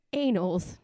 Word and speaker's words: annals: No, Matt Carlson wrote that the album, quote, will go down in the annals. No, I don't know. – The banals of history? – The banals. The annals. How do I pronounce it? – Annals.